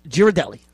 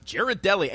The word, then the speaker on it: Ghirardelli: Ghirardelli. – Ghirardelli, and you know how to pronounce that. Congratulations.